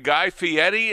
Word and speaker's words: Fieri: Last night, Guy Fieri, is that how you pronounce his last name, Tony? – Yes.